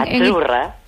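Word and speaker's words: Azzurra: Azurra. – Azurra. Mira, ¿ves? – Azurra. Es que... Sí, como porque pizza, la Z no es la Z como decimos en catalán tan sonora, no.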